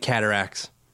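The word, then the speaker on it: Cataractes: Like cataracts. – It sounds like Katagat. – Oh, Cataract. You got to roll that R, Matt.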